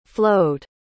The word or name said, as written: flowed